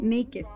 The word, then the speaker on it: Neiked: N E I K E D, Naked, como suena, pero no como se dice en inglés. Ah, pero ¿cómo suena? – Naked. – Y entonces te dicen, ¿estás naked?